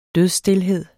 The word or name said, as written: dødsstilhed